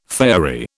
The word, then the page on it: faerie: British English